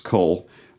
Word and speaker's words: Caol: That's what the C-A-O-L, it's pronounced Cull, means Kyle, which is a Narrows.